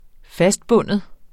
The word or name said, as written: FASTBUNDET